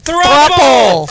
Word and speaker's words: thruple: Thruple. Thruple. It rhymes with couple.